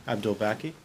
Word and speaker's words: Abdulbaki: Hi, I'm Zack Abdulbaki, and I'm a writer, director, producer. – Get closer to the mic. How do you say your last name? – Abdulbaki.